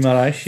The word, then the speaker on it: Guimarães: Who went to Victoria Guimarães, I think is how you pronounce it. – Guimarães, for 1.2 million euros.